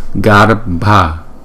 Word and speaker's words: Garbha: Nhưng mà chữ B, H, A là chúng ta đọc là Bha. Không? Garbha.